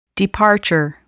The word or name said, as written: departure